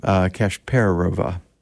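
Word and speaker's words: Kashperova: I just murdered her name, but Kasperova. No, the emphasis is on the per, Kasperova.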